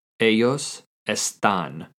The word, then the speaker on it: ellos están: In some countries, it also might make a J sound, such as ellos or ellas. To say they are in Spanish using the verb estar is ellos están. Ellos están. This is spelled E-L-L-O-S space E-S-T-A-N with an accent on the A in están. Ellos están.